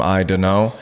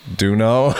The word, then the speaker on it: Dano: And I also found out we were kind of picking on Roberto Dano, calling him I Dano. – Yeah. – It turns out that's a long U, so his name's pronounced Duno.